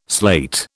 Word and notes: slate: British English

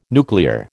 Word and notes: nuclear: American English

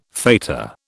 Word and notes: fetor: British English

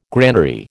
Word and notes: granary: American English

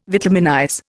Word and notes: vitaminize: Indian

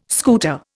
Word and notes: scooter: Indian

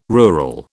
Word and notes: rural: British English

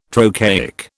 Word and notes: trochaic: British English

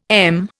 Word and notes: em: Indian

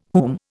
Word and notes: whom: Indian

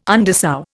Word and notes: undersow: Indian

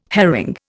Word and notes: herring: Indian

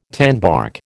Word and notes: tanbark: American English

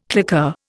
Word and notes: claqueur: Indian

